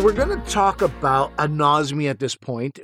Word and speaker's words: anosmia: I know about anosmia.